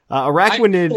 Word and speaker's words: Araquanid: Which is going to be Araquanid? Is that did I say that correctly? – Araquanid.